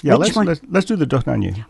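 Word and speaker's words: Dohnányi: It's just Dohnanyi. – Dohnanyi. – I thought Dohnanyi. – So he doesn't... – I think it's first syllable.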